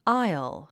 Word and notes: isle: ǽil; アイル

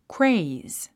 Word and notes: craze: kréiz; クレイズ

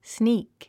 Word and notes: sneak: sníːk; スニーク

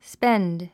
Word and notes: spend: spénd; スペェンド

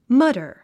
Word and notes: mutter: mʌ́tə r; マタァ

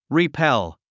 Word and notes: repel: ripél; リィペェゥ

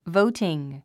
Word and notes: voting: vóutiŋ; ボウティン

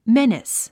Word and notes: menace: ménis; メニス

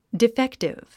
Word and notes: defective: diféktiv; ディフェクティブ

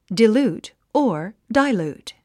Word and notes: dilute: dailút; ダイルート

